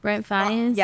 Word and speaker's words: Brent Faiyaz: Brent Faiyaz? – Yes.